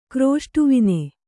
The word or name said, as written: ಕ್ರೋಷ್ಟುವಿನೆ